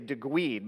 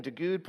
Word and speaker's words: Duguid: Then you have an individual named, and I've mispronounced his name in the past, but I think it's Ian Duguid. I wanted to say Duguid because it's D-U-G-U-I-D, but it's Ian Duguid.